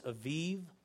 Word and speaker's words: Aviv: And Aviv, I know it looks like a bib, but it's pronounced Aviv, literally means spring.